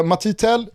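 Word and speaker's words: Mathys Tel: Han heter då alltså M-A-T-H-Y-S och så i efternamn T-E-L. Det gör att min franska vill liksom uttala det Matti Tell. Alltså jag tror inte, fransman. – Jag tror inte man uttalar det Mathis direkt. – Okay. – Men så att Matti Tell tror jag är det korrekta franska uttalet på den här 17-åriga anfallaren.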